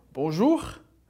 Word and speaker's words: bonjour: Então essas três palavras que servem de exemplo pro R pronunciado no final de palavras, de novo, bonjour, amour e au revoir.